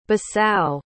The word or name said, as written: Bissau